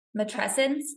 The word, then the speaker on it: matrescence: Matrescence, is that how you say it? – Yeah.